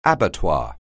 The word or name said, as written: abattoir